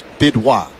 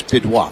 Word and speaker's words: Bidois: The last name is pronounced Bidwa. B-I-D-O-I-S. Bidwa.